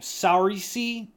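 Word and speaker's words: Saoirse: Sarais. – I'm pretty sure her name is Sarais. I think that's how she says it. I think it's Sarais Ronan.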